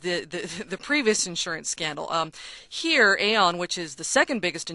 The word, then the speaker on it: Aon: How about this company, Aon? Am I pronouncing that correctly? It's spelled That's correct. – A-O-N, another insurance broker, and they're tied up in this as well.